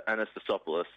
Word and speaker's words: Anastasiopoulos: Got a great first name, no different to yours, three letters, Tom Anastasiopoulos. Is that how you would pronounce it? – I'd go Anastasopoulos.